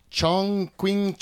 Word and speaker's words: Chongqing: Chongqing, China.